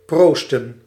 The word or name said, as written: proosten